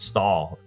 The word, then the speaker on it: Stoll: Speaking of names, just quickly I'll mention that the surname here is spelled S-T-O-L-L, which looks like stole, but as author William Plunkett explained, they pronounce it Stahl down in Louisville.